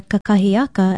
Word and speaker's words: kakahiaka: We often hear kakahiaka as a part of aloha kakahiaka, a greeting early in the day. And most know it means morning. Kakahiaka is spelled K-A-K-A-H-I-A-K-A with no pronunciation marks.